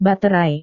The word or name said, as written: baterai